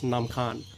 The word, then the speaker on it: Nam Khan: In Lao, it's pronounced Nam Khan. Nam means water.